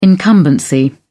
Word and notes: incumbency: British English

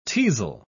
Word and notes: teazel: British English

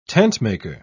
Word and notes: tentmaker: British English

